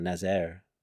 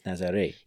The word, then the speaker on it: Nazaré: Yeah, because, like, sometimes you see the letter E with an accent, like in the town Nazaré. – Nazaré. – But that's to inform the reader that you have to do the A, so Nazaré, it's not Nazaire, right? It's Nazaré.